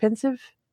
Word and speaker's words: Pensieve: how you say it, pensive? Pensive.